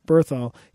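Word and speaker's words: Bernthal: John, how do you pronounce his last name? – Berthol.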